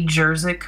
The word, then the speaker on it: Jerzyk: My Instagram name is K Jerzyk Photo. My last name is spelled Jerzyk.